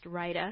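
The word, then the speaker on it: Rayda: It's R-A-Y-D-A, but it's pronounced Rida.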